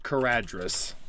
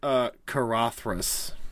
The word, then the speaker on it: Caradhras: Yeah, you pronounce it Carathras. You Carathras. Do not say Caradhras.